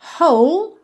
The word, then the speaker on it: whole: Duas palavras aqui que algumas pessoas podem achar que elas são difíceis de pronunciar, talvez pela escrita, mas na verdade não são. Whole, whole, tá? W-H-O-L-E whole, whole. E through, T-H-R-O-U-G-H, through, through. Quite simple, right?